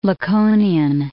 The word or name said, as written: Laconian